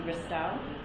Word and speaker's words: Ristau: My name is Trinity Ristau. Last name is spelled R-I-S-T-A-U.